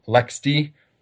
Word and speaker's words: LXD: So you also have, for example, LexD, which is a platform whose name is spelled LXD.